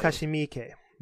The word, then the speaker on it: Takashi Miike: Takashi Miike.